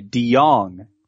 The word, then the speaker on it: DeJong: Is it Chase DeJong and Paul DeJong? Or Chase DeJong and Paul DeJong? – DeJong. DeJong is how Baseball Reference says to pronounce it.